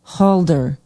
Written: halder

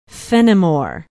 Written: Fennimore

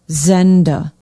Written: zenda

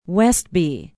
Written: Westby

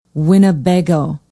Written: winnebago

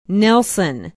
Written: nelson

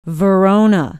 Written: verona

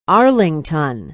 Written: arlington

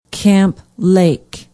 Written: camp lake

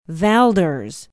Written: valders

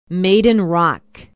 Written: maiden rock

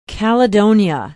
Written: caledonia

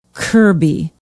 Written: kirby

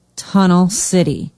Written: tunnel city

